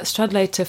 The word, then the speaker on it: Stradlater: There's also a disgust at, is it Stradlesser? I can never know how to say it. – Stradlater. – Oh, yeah. – That's how it's spelled.